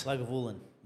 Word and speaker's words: Lagavulin: So it's Lagavulin. – Yeah. Is that how you pronounce it? – That's how you pronounce it. Nibba's confirmed that with me.